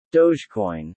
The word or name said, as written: dogecoin